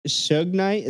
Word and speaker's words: Suge Knight: But how do you say his name? Suge Knight? Is that how you say it? Suge? – Yeah.